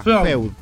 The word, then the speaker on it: Feud: Se llama Feud, Feud, no sé cómo se pronuncia, sorry, pero busca Feud.